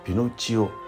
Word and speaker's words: ピノキオ: 綴を英語式にピノチオと読む